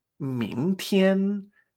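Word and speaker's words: Ming Tien (明天): Hemos dicho que la palabra de referencia es mañana, que es Ming Tien. Ming Entonces, lo que tenemos que tener presente es que Ming, segundo tono, me extraño. Tien, primer tono. Ming Tien. Perfecto.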